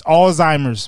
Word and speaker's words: Alzheimer's: I got old timers. – I said it right. – I'm saying that's how most people refer to it. It's Alzheimer's, by the way. We try to educate.